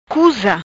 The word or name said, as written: Cuza